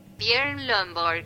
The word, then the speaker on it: Björn Lomborg: De acuerdo a mis registros, el nombre de este autor se pronuncia de la siguiente forma, Björn Lomborg, y se deletrea así, B de bueno, J de Javier, O de oso, R de ratón, N de niño.